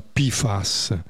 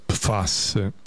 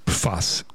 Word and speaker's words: PFAS: Dai, vi parlo dei PFAS, che in inglese si pronuncia PFAS, ma in italiano si pronuncia PFAS. Mi sono informato. E questa è la pronuncia corretta, PFAS.